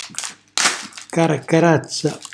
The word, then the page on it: carcarazza: calabrese